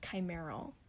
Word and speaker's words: chimeral: It looks like chimera. Chimeral? – Chimeral. – But it's actually pronounced chimeral.